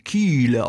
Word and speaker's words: Kyle: Ja, hat man relativ lang Kiele ausgesprochen. Holstenstadt Tom Kiele. Und das heißt, die Holstenstadt an der Kieler Förde. Das heißt das übersetzt, ja. Und damals hat man das noch mit so langem I ausgesprochen. Also K-Y-L-E wird es geschrieben, dann Kiele, ne?